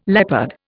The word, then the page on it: leopard: Indian